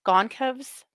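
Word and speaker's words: Gonçalves: Modin and Gonka, every time I say it wrong, Goncalves.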